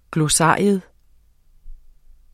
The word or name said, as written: GLOSSARIET